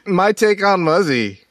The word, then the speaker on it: Muzzy: Wait, how do you say his name? – Muzzy. – Muzzy? – Muzzy.